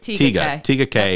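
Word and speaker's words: Tega Cay: So 66 is Tega K. Am I saying that right? – Tega K, yep.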